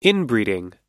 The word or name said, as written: inbreeding